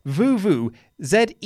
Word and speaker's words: vuvuzela: also says that Vuvuzela, which we've been talking about a lot, is actually pronounced Vuvuzela. The Z is the same as zebra, not the American Z.